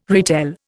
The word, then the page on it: retell: Indian